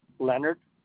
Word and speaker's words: Leonard: Leonard, L-E-O-N-A-R-D. Glover, G-L-O-V-E-R.